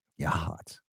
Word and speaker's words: Yachats: It, it, it's spelled Y A C H A T S. Yahat. And it's spelled. It, yeah, it's pronounced Yahat.